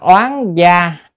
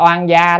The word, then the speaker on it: oán gia: Oán, chữ oán này là oan, chúng ta hay đọc là oan gia đó, oan gia là dịch nghĩa, còn đúng ra là oán gia là đọc âm.